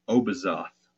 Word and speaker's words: Obizoth: In other texts of the period, Hellenistic and the Testament of Solomon, it's the same entity, and she's called Obizoth, O-B-I-Z-O-T-H, or Abizu. And you can spell that A-B-Y-Z-O-U or A-B-I-Z-U.